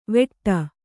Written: ವೆಟ್ಟ

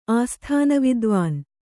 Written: ಆಸ್ಥಾನವಿದ್ವಾನ್

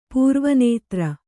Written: ಪೂರ್ವನೇತ್ರ

